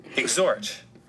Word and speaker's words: exhort: Exhort. – That's the word. That's how you pronounce it correctly.